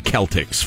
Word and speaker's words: Celtic: It's you don't pick the Cavaliers over the Celtics, for instance. And please pronounce it Celtic. It's a culture, not a costume.